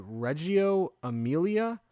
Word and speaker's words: Reggio Emilia: The second one, and I'm going to say this wrong, it's called Reggio Emilia, which is like a holistic approach. – You said it right.